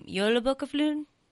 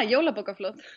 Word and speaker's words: Jólabókaflóð: And I think it'd be so cool if – I think it's pronounced Jólabókaflóð. – Yeah, Jólabókaflóð.